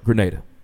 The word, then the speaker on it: Grenada: So, you are from, I want to make sure I pronounce this right, Grenada.